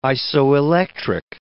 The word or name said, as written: isoelectric